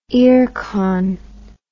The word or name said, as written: earcon